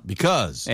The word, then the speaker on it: because: Because 발음을 주의하세요. Because, no, no, no. 뭐예요? – Because. – 네, because고요. 영국식은 because. 이렇게 입술이 한 번만 움직인다는 거 기억하시면 되겠습니다. 자, 그렇다면 마무리하겠습니다.